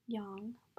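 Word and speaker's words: Yang: Young, but... – Oh. – That's how you say it in Korean.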